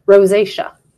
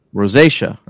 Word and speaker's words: rosacea: Rosacea. – Rosacea.